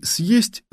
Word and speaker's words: съесть: В слове «съесть» звук «е» произносится не так, как мы его обычно выговариваем в середине слова, а так, как в начале, не как «е», а как «е». Ведь в начале слов буква «е» означает всегда не звук «е», а два звука «и» плюс «е».